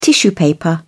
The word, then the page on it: tissue paper: American English